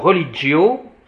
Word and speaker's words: religio: Alors, religio, hein, le G, c'est DJ, religio. Tandis que là, c'est religio.